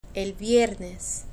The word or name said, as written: el viernes